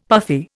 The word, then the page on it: puffy: Indian